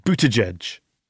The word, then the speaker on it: Buttigieg: Am I saying your name right? Buttigieg. – You got it.